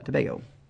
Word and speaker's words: Tobago: Tobago.